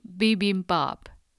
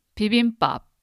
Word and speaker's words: bibimbap: So how do Koreans actually pronounce bibimbap? It's bibimbap. It's very much the same, but the tone is slightly different, I think. Not bibimbap, it's bibimbap.